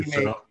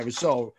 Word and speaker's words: Rousseau: I didn't get a chance to really see and read up on it, but I know he's actually kind of leaped over Rasau, or whatever, however you pronounce his name. – I think Gregory Rousseau. Yeah, Rousseau,